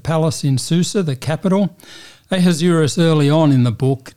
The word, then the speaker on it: Ahasuerus: Although he's called in the book Ahasuerus, at least that's how I pronounce it. – Yes, I said Ahasuerus, but I don't know either.